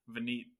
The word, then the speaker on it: Vineet: It sounds like Vineet. Is that how I should have asked how to pronounce your name before, but is that how you say it? – Yes, that's exactly how you say it.